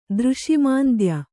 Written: ದೃಷಿಮಾಂದ್ಯ

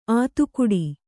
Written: ಆತುಕುಡಿ

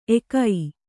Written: ಎಕೈ

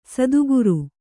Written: ಸತ್ಕಾರ

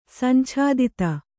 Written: ಸಂಛಾದಿತ

